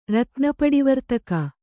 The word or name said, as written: ರತ್ನಪಡಿವರ್ತಕ